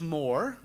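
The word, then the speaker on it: Mohr: See, Joseph Moore, which is spelled O-M-O-H-R, was an assistant priest in a little congregation in Oberndorf, Austria.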